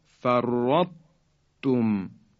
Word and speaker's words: فرطتم: به این خاطر بایستی دقت کنیم و اینطور تلفظ کنیم، فرطو، فرطو، اثری از درشتی حرف طا باقی مونده. یا کلمه احطو، احطو، یا فرطتم، فرطتم، این حرف طا دسته داره که ساکنه بعد حرف ته دو نقطه اومده.